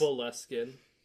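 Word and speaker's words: Boleskine: Is it Bullskin? – What the hell is this? It's Beliskin. As far as I know, it's Beliskin. That's what I've heard it pronounced as.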